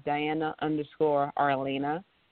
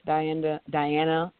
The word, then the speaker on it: Arlana: And on Instagram, my personal handle is Diana_Arlana. And that's spelled A-R-L-A-N-A. Diana_Arlana on Instagram is where folks can find me.